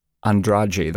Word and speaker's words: Andrade: Well, there's a woman, the writer and director is Suzanne Andrade. That's how I'd say her name, A-N-D-R-A-D-E.